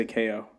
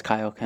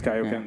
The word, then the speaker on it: Kaioken: He comes out with Kaioken. – Yeah. – I said it right? – Yeah. – Finally. I usually say Kaio. – Yeah, it's Kaioken. – It's Kaioken. Yeah.